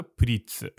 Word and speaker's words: Pretz: Yeah, pretz, is it pretz or pritz? – Pritz. – Pritz, huh? Even though it's with an E? Twist. I've been saying it wrong the whole time. – No, well, it's written with an E, and it also comes from the word pretzel.